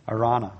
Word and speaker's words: Araunah: Somebody told me how to pronounce it. I forgot already. Araunah. It's Araunah. That's it.